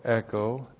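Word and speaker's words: echo (ἔχω): Hina plus the subjunctive, H-I-N-A. Here it introduces a result clause. And then we have the verb echo. E-C-H long O, echo.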